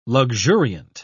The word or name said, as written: luxuriant